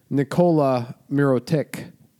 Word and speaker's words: Nikola Mirotić: So let's talk about Nikola Mirotić. – Nice. That's how he wants to be pronounced.